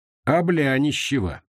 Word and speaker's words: Облянищево: «Облянищево!» Он произнес название деревни с ударением на «бля».